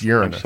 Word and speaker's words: Uranus: That is pronounced Uranus.